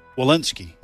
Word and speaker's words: Walensky: Walensky.